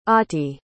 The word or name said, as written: ottie